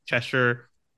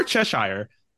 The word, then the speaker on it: Cheshire: And although it was an Elseworlds story, it does become popular enough that Roy, after developing this relationship with Cheshire, or Cheshire, I'm not bad about this. I know you were actually pronouncing it as Cheshire at some point in time. Both names actually work, apparently.